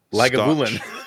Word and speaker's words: Lagavulin: Lagavulin Lagavulin.